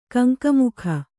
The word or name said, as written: ಕಂಕಮುಖ